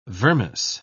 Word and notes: vermis: British English

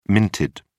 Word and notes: minted: British English

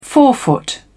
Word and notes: forefoot: British English

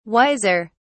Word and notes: wiser: British English

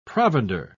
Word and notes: provender: British English

